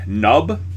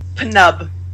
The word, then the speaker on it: Nub: I think it's Nub. I don't think P is. – They've been pronouncing the P. – Have they been pronouncing the P? It pisses me off so much. Why? Why do you do that? I hate it. – I mean, technically, the P and the N are supposed to kind of blend together and just be Nub.